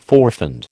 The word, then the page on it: forfend: American English